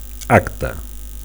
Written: acte

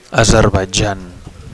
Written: Azerbaitjan

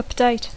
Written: update

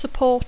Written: support

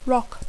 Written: rock

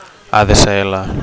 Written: ADSL

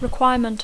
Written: requirement

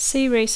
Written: series

